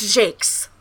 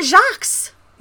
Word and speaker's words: Jacques: Apparently that is how his last name is pronounced when I was doing research. His last name is pronounced Jakes.